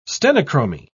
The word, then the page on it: stenochromy: British English